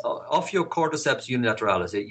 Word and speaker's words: Ophiocordyceps unilateralis: So this is called, and I'm probably screw this up, I'm going to try it. Ophiocordyceps unilateralis? – Yes, Ophiocordyceps unilateralis. You said it correctly.